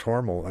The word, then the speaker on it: Hormel: But in Austin, Minnesota, it is still pronounced Hormel, and I was there recently, like weeks ago, and I fell very easily into the old pronunciation.